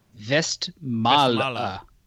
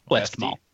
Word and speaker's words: Westmalle: So we have a pronunciation, Westmalle, or colloquially Westmalle. Westy if you're from Boston.